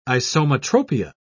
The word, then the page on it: isometropia: British English